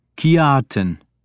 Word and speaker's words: Kjartan: "Kjartan, is that how it is pronounced?" – "Kjartan," I corrected him, making the J sound like a Y.